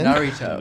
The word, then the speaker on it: Naruto: Last I think it's it's kind of like pronounced like Dorito. – Yeah. – Well, with an N? – Naruto.